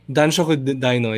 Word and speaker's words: Danshoku Dino: Uh, I got to see uh, Dankoshu Dino. Tama ba? Am I getting his name right? – Danshoku Dino, yeah.